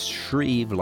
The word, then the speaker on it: Schreve: It's Shreve, like Shreveport, Louisiana, although it's spelled a little different, S-C-H-R-E-V-E.